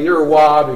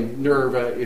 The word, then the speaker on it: Nerva: Now, I'm saying Nerva because the Latin V is pronounced like a W. You can say Nerva if you want to, and I wouldn't know if either one of us is really pronouncing it right.